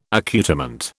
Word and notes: accoutrement: British English

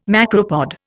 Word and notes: macropod: Indian

